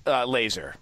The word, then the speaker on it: Lazor: OC Bill Lazor, is that how you pronounce his name? – Laser. – Laser. – Yeah, Bill Laser, yeah.